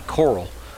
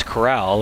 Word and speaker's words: Corral: I keep wanting to say Coral, but it's Corral, and with the G, all I can do is say Golden Corral.